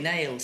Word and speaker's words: O'Neill: And look there, she says, "Now there's little Molly Nail." See, her maiden name was Molly O'Neill. And in Ireland they sort of make their own pronunciation on it, so it's Molly Nail.